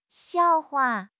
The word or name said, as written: xiao4 hua5